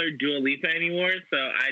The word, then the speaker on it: Dua Lipa: Dua Lipa? Am I saying that right? – Dua Lipa, yes, yes. You're saying it, you're already saying it better than Wendy Williams.